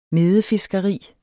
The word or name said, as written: medefiskeri